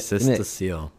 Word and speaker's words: Cystocele: Cystocele.